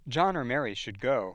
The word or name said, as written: John or Mary